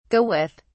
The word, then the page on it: go with: British English